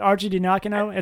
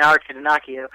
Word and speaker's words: Archidiacono: Now that I know how to say it, Archie Diacono, it's a little bit easier to say. – I would be saying Archie Diacono.